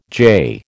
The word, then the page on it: jay: American English